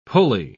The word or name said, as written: pulley